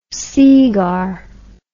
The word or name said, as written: seegar